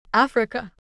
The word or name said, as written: Africa